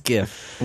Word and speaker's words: GIF: What does the G stand for in the word GIF? It stands for graphic. – Yes. – How do you say graphic?